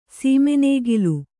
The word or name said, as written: ಸೀಮೆನೇಗಿಲು